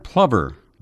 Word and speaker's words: plover: The word plover, by the way, is spelled P-L-O-V-E-R, but it does not rhyme with clover.